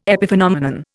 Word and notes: epiphenomenon: Indian

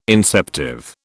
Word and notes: inceptive: British English

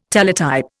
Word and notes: Teletype: Indian

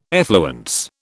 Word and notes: effluence: British English

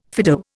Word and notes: Fido: Indian

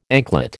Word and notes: anklet: American English

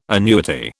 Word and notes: annuity: British English

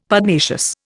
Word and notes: Pugnacious: Indian

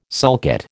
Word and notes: sulcate: American English

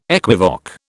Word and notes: equivoque: British English

